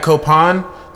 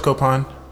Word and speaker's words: Copán: Today we had Copán. Thank you so much, Copán.